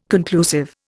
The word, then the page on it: conclusive: Indian